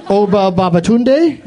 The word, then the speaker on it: Oba Babatunde: And your 5 names are Martine Biswicki, Oba Babatunde. Mm. I know I'm pronouncing Oba Babatunde right because I love that name.